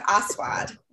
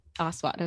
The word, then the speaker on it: Aswad: So, and I think I'm pronouncing it properly. His name is Paul Aswad. Okay. And it just, it's like A-S-W-A-D. So he says, well, pronounce it differently, but we're a positive podcast. – That's right. So I'm going with Aswad.